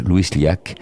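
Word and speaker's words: Lluís Llach: Vous pouvez prononcer euh, le nom Il s'appelle Louis Yac, de l a c h Louis Liac.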